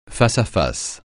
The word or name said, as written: face-à-face